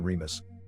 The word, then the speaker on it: Remus: In Latin, their names are pronounced with the accent on the second syllable, Romulus and Remus.